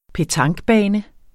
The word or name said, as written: petanquebane